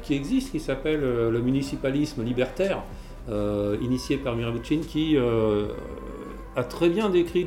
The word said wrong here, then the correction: Murray Bookchin: Redis le nom s'il te plaît. – Murray Bookchin. Murray Bookchin. voilà. J'ai mal prononcé, effectivement. Merci de, me reprendre.